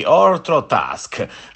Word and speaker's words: Ortrotasce: Systems of Love di Orthotask. Sarebbe scritto Orthotasche, però sono andato a cercare come si pronuncia, perché chiaramente è impossibile altrimenti.